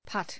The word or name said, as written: putt